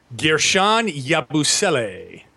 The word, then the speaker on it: Guerschon Yabusele: Guerschon Yabusele. – And that I love how you say it with the French flair, man.